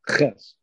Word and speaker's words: ches: If you close up that last leg, it becomes a, not a hey, but a ches. – But a ches, correct. It's a ches.